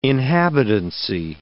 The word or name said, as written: inhabitancy